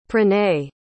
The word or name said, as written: pranay